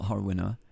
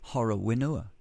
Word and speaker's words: Horowhenua: In Horowhenua, is it? No, it's not Horowhenua. It's Horowhenua. No, it's not that. What is it correctly? The correct pronunciation of that word. Down near Levin. Well, it's actually Horowhenua. It's got an aspirated WH.